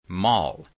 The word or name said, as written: mall